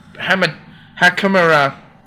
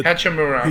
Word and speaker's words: Hachimura: Rui, I don't know how to say this guy's name for the life of me, but Rui Hakimura He's good. – Hachimura.